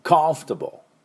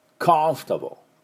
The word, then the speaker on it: comfortable: はい、で F の音、フッフッフッしっかりね、で最後コンフトボーはですね、ダブルシーン、ブルブルブルブルブルで E は発音しません、ね、コンフタブレでありませんのね。では私の後で発音いきましょう。 comfortable。 comfortable。